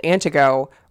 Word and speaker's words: Antigo: And so we lived in a town called Antigo. We don't pronounce the T.